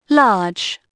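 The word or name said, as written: large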